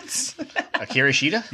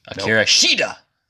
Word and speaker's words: Hikaru Shida: Hikaru Shida? – Yes. It's like I put too much emphasis on the I, and then my brain just like Hikaru Shida.